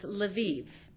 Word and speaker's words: Lviv: This city in the left, in the west, Ukrainian, it is pronounced Lviv. In Russian, it is Lvov.